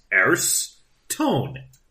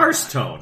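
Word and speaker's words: Aerstone: William Grant and Sons, Arstone. I think that's what it says. Arstone. Yeah, I'll go with that. – Shift your pronunciation. Just there. – A-E-R-S. Arstone. Never Arstone.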